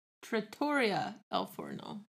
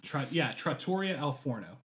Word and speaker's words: Trattoria al Forno: So we went to, forgive me for mispronouncing this, but I believe it's Tratofino al Forno? – Trattoria al Forno. I took a picture of the restaurant. – Trattoria al Forno. That's it.